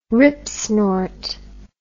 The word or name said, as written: ripsnort